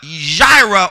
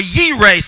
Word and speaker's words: Yireh: So everybody say Yireh with me. Yireh, like Y-E-E, Yireh. Yireh is a Hebrew pronunciation of the word provider. When we say Jehovah Jireh, we really should be saying Jehovah Yireh.